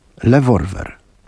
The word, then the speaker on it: Leworwer: Natomiast teraz jeszcze ostatni utwór na tej płycie Hani Rybki, płycie i to, który nazywa się Leworwer. Niech Państwo zwrócą uwagę, to nie jest błąd w wymowie. Tak właśnie się nazywa. Leworwer.